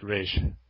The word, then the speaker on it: Ra's: No, that's but We're going to make a lot more people call him Raz now, more than Raj. – You see, I call him Raz. – That's wrong. – I don't really care how it's pronounced. But you got to pronounce it Raj.